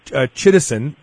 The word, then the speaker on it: chitosan: I pronounce it chitosan. How do you pronounce it? – Yeah, same way.